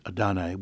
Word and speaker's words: Adonai: This is the name of God given to Moses, the consonants of which are YHWH, pronounced Adonai, which means, or Adonai, which means Lord in Hebrew.